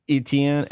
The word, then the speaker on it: Etienne: Etienne. Yep. It sounds like gumbo.